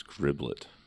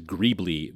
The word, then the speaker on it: greeblie: So, yeah, I used to think that word was griblet. – Griblet. – But gribbly makes sounds way better. – I've always read it as greebly, which is spelled G-R-E-E-B-L-I-E, and then an S if it's plural.